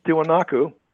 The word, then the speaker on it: Tiwanaku: Tiwanaku? I'm not quite sure how to pronounce it. – Tiwanaku.